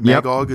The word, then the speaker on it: Magog: Is that Magog? Yep. Is that how you say it? – Yeah.